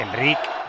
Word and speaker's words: Enrich: Enric, que se pronuncia Enric.